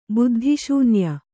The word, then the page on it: ಬುದ್ಧಿಶೂನ್ಯ: Kannada